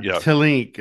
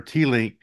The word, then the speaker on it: Teellinck: Willem, or as you said, proper pronunciation, Willem. Tilink or Tilink. He's a Dutch author. You spell his last name T-E-E-L-L-I-N-C-K.